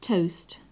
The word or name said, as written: toast